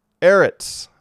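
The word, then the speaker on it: Eretz (ארץ): Eretz. I mispronounced it a moment ago. Eretz, not Ertz. Eretz.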